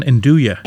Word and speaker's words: andouille: Pronounce it again, andouille. – Andouille. – I say nandouille. Yeah, nandouille. – Andouille.